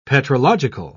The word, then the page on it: petrological: British English